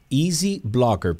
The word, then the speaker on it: EZ Blocker: Eh, uno se va a la página de, wow, Easy Blocker, pero se escribe EZ Blocker.